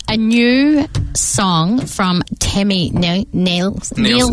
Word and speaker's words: Nielsen: I always want to pronounce it differently. I don't know why. It's because it's got an I in it. And I think Nelson, but it's not. It's Nielsen. – Nielsen.